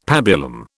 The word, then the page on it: pabulum: British English